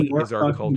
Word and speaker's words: Norfolk: Which is spelled like Norfolk. – Uh-huh. But they say Norfolk. – In the town's history, they will tell you that that's because the postmaster misspelled it. And Nebraskans are too nice. They didn't want to hurt his feelings. So they were like, we can spell it that way. It's fine. they pronounce it Norfolk.